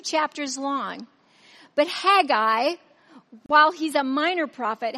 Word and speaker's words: Haggai: I'm pronouncing it Haggai. Bible scholars will pronounce it Haggai.